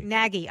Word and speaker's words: Nagy: Nagy.